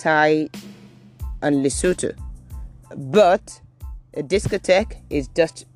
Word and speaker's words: Lesotho: Then you could have names of places like Thai, Thailand, Lesotho. Lesotho, that's the way to pronounce that word.